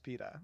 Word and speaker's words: PETA: At PETA said, it's pronounced PETA, no fur baby. – I know, you're right, it is PETA actually.